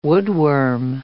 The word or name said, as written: woodworm